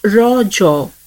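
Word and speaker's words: Raja: The festival is natively pronounced as Raw Jaw, a local term that means menstruating woman.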